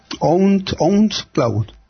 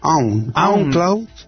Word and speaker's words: OwnCloud: Mis pronunciaciones, madre mía. A ver, OwnCloud. Sí, OWN. – OwnCloud.